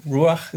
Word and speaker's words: ruach: And obviously we know a Hebrew word for spirit is ruach.